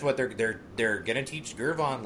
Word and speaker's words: Gervon: You keep hitting that hard G like the man's name isn't Gervon.